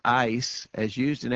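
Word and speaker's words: eis (εἰς): The Greek word there for the remission of sins, the Greek word is pronounced eis. It's usually anglicized. It's spelled E-I-S.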